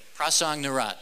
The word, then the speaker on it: Pak-Prasong Norack: The Pak-Prasong Norack is my real name. – Pak-Prasong Norack. – That's correct.